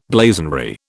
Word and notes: blazonry: British English

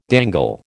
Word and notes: dangle: American English

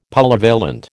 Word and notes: Polyvalent: American English

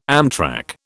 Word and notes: amtrac: British English

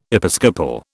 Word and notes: episcopal: British English

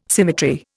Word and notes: symmetry: Indian

